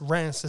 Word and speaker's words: Reims: just south of a major city center called Reims, or Reims, R-E-M-S. Reims is how I'm probably butchering it, but that's how they pronounce it in French.